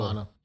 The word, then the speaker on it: Havana: Havana. – I'm not sure how to pronounce it. – Yeah, I understand. That's how you say it. – Okay, cool. – Havana.